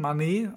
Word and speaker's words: Mané: Jetzt habe ich da von unseren BVB-Reportern gehört, dass dieser Filippo Mane, angeblich Mane, sogar ausgesprochen, Mané, eigentlich sogar mit Accent aigu, also sehr komisch, angeblich Filippo Mane, der...